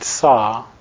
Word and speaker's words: Sau: As you naturally breathe out, without trying to control the breath at all, you mentally chant the word Sa, as in hammer and saw, only it's spelled S-A-U.